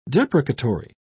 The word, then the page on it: deprecatory: British English